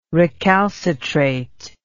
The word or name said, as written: recalcitrate